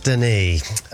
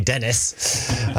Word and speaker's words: Denis: Blondie, and I believe it's pronounced Denis. only an idiot would on the radio would say Dennis.